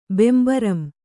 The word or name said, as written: ಬೆಂಬರಂ